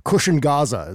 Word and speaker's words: Cushion Gaza: He says, I really like your album, by the way, Cushion Gaza. Is that the pronunciation of that one? – That is pronunciation.